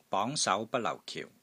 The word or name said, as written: BONG2 SAU2 BAT1 LAU4 KIU4